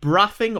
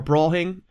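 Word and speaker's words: Braughing: At the village of Braffing, or Brawing, if you're from there, apologies. It's spelled like laughing, but with a B-R at the start, in Hertfordshire.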